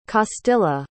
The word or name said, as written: castilla